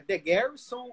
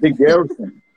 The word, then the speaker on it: The Garrison: E, cara, como que veio a, a ideia do The, é The, Garrison, ou como que, como que fala? Estou falando certo? – The Garrison, The Garrison mesmo.